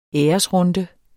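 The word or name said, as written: ÆRESRUNDE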